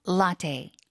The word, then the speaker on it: latte: 이 라떼보다 영어로 읽을 때는 라테에 가깝죠. 테이. – 네네네. – 약간 테이. 라테. 이게 계속 발음이 안 잡히는 경우가 많기 때문에 이번에 그냥 발음 확실히 잡아주세요. 세 번만 해주세요. – Latte. – Latte. – Latte. Latte. – Latte. – Yeah. – Latte. 그때 약간 테이처럼 테이프할 때 테이처럼 문장 다시 한번 읽어드릴게요.